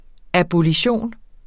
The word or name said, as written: abolition